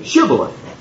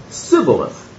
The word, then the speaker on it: Shibboleth: They could not say Shibboleth. They pronounced it Sibboleth. They couldn't say Shibboleth to save their life.